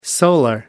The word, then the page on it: solar: American English